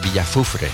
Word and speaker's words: Villafufre: Flipa el nombre de ese pueblo, tío, Villafufre. – Sí, es complicado de pronunciar a veces. – Es Villafufre.